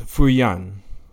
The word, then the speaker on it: Feuillan: Um, welcome, Norbert Feuillan.